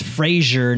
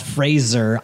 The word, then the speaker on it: Fraser: It's pronounced Fraser, not Fraser.